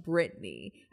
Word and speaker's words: Brittany: Brittany is usually spelled B-R-I-T-T-A-N-Y, Brittany, but it's pronounced Britney.